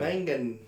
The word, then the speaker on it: Mangan: Because normally I mix in a recording, but I mean, you got your guitar here with your new how do you say that last name? It's Kurt Mangum. – Mangum, okay. – Mangan. – Mangan with an N, okay.